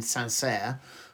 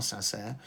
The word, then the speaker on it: Saint-Cyr: He did, and then he went to the Military Academy in Saint-Cyr. I think that's the correct pronunciation, C-Y-R, Saint-Cyr.